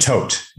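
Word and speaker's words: Toht: Tolt. Is it Tolt? Am I saying that right? – Tote. Yes. – Tote. Yes.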